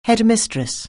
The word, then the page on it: headmistress: anglais